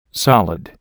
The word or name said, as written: solid